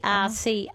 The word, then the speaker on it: Cercis: C Cercis.